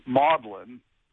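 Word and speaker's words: Magdalen: spelled differently, but both of them pronounce them Maudlin.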